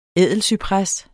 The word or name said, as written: ædelcypres